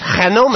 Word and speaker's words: GNOME: GNOME. – Did you know that the Spanish pronounce it Genome? – Genome!